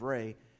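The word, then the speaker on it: Ra: It's spelled R-A, but it's pronounced Re.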